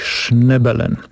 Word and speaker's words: Schnebelen: Gdyby Państwo szukali informacji w sieci, to nazwisko pisze się tak z niemiecka, SCH, a potem tak jak słychać Schnebelen. Nick Schnebelen.